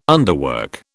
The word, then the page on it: underwork: British English